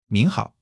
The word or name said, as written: 您好